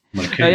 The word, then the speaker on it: Mulcahy: Mulcahy.